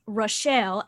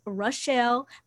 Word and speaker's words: Rochelle: It is Rochelle, as I have told this gentleman many times. He's been up in my store at least 8 times, and I have said, it is Rochelle. I know it is spelled exactly the same as Rachel, but it is different.